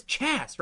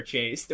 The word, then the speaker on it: chaste: Obviously, the word is chast, right? You got any words like that that the pronunciation annoys you? And she was like, no, it's for sure chaste.